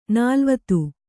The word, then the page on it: ನಲವತ್ತು: Kannada